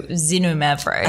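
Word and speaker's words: Xinomavro: It's spelled X-I-N-O. Mavro, so Zinno Mavro.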